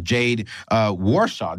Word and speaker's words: Jade Warshaw: Jade Warshaw.